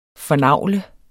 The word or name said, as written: fornagle